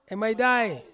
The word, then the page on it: maidai: Ansus